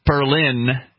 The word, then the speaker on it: Berlin: The company, which is also, I'm sorry, I pronounced that the New Hampshire way. Berlin is how you pronounce it, the international way.